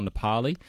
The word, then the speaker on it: Nepal: Now, Rainer from Noosa has sent through a text about the pronunciation of Nepal, and you are hearing Nepal a lot, or Nepali. So we're going with Nepal, or the ABC is going with Nepal, to be consistent with the local pronunciation of how it would be said there in Nepal, I guess, to be consistent and, I guess, respectful to some degree to the local dialect.